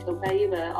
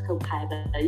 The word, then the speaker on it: Copaiba: Copaiba, also known as Copaiba. You can say it like that too, but it is one of the most anti-inflammatory substances on earth, and it comes straight from a tree.